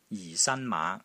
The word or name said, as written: JI4 SAN1 MAA5